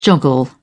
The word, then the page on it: joggle: British English